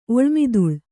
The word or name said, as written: ಒಳ್ಮಿದುಳ್